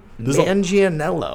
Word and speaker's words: Manganiello: So the biggest thing is he meets Joe Manganiello. See, I learned how to say it. Thank you. – Yeah, Joe Manganiello.